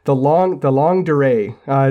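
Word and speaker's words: longue durée: The long durée, is that correct? – Yes, that's perfect.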